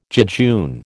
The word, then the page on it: jejune: American English